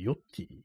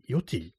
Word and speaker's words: YOTY: そのユンキーがね、こう、今度、あのー、スケートビデオを出すってことでね、スケートムービーっていうね、それは YOTY、 YOTY ti って書いて、ヨッティ、ヨッティって読むらしいんですけど、